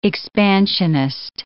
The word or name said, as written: expansionist